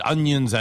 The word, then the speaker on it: onions: Burgers surely need onions and ketchup. – 주의하세요. 발음이요. 이거 onion 아니에요. O-N-I-O-N은요. any 하나임에도 불구하고 발음이 언니 언처럼 들려요. – onions. – 예, onions 해서 발음이 언니. 어, 누나, 언니! 그래서 onions.